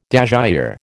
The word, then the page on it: dasyure: American English